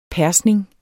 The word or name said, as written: persning